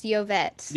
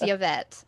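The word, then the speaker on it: Llovet: We did, and it was Yovet. – Yovet, right? – I think. We figured this out, but we've kept saying it wrong so often that I'm not sure, but I think it's Yovet.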